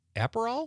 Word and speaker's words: Aperol: Liz from Sun Prairie says she's going to have tequila and soda with a lime or a splash of Aperol.